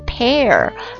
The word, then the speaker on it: pare: 来看下面一个，下面一单词它跟梨，水果梨子的那个读音是一模一样的， pear， pear，但它写法呢不一样， p-a-r-e， p-a-r-e， pear，那 p-a-r-e 这个 pear 动词啊表示什么意思呢？